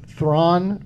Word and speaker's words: Thrawn: Thrawn rhymes with Zahn.